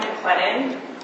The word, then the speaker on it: McLennan: Anne McLennan, please. – M-C-L-E-N-N-A-N.